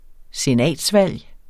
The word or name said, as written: senatsvalg